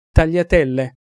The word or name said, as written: tagliatelle